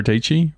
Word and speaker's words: Deechi: Sarah Deechi, rhymes with peachy.